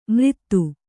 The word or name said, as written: ಮ್ರಿತ್ತು